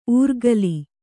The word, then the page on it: ಊರ್ಗಲಿ: ūrgali